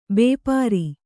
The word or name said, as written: ಭಪ್ಪರೆ